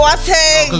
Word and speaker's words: Boateng: Boateng's fine. Boateng is like... – Boateng! – Well, you know, in the Ghanaian way is Boateng, but it's fine.